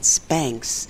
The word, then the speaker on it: Spanx: That's right, here in Bonoboville, ever since we realized that Spanx rhymes with thanks back around the turn of the 21st century, we've been celebrating Spanksgiving on the Saturday before or after Thanksgiving.